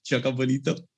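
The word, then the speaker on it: jogo bonito: I mean, I don't know if I'm pronouncing it right in Portuguese. Ollie, please feel free to correct my pronunciation. But yeah, is that okay? Is that right? Joga Bonito. Oh, perfect.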